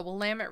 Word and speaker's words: Willamette: Willamette River, making Portland a great hub for ships to land and distribute goods. – You nailed that pronunciation. Good job. The Willamette River.